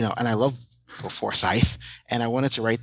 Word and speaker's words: Forsyth: His name is pronounced Bill Forsyth. He said that it's not Forsyth, and I will never get it right because I'm so used to saying it the wrong way.